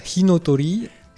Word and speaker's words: Inotori: Est-ce que j'ai à peu près bien prononcé ? – Voilà, Inotori.